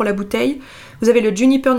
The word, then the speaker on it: JNPR: Ensuite, une autre marque dont j'entends beaucoup parler, je pense que ça se prononce Juniper, ça s'écrit JNPR, une marque de spiritueux premium, je n'ai rien inventé, c'est eux qui le disent.